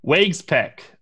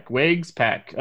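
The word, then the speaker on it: Waguespack: You know, people hate when I pronounce names wrong, and I've tried to get better with it, so I looked it up, but it's Wagspeck.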